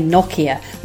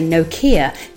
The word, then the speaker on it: Nokia: The British will say Nokia, which is a fine whisper away from what's said in Finland. If you say Nokia, then you are a country mile from the Finnish head office pronunciation.